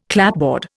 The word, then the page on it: clapboard: Indian